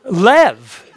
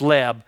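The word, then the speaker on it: Lev (לב): Lev. Now, I know it looks like it's Leb, but I pronounce it Lev.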